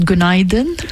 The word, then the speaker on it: günaydın: Because I'm learning Turkish, and I said günaydın. – Yes. – Which is? – Good morning. – Good morning. So, and she says my pronunciation is good.